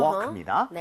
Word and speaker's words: walk: 아, 요거는 묵음이에요. – Uh-huh. – walk입니다.